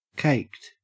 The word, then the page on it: caked: Australian English